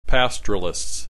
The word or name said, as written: pastoralists